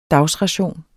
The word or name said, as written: dagsration